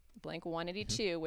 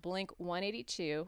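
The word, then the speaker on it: Blink-182: Blink 182, which I now just learned today, and Tom DeLonge confirmed on Twitter recently, it's not Blink 182, which is how the Americans pronounce it. Apparently, the British pronounce it Blink 182. – Blink 182. – 182. – That was a horrible accent. – But it's apparently pronounced Blink 182.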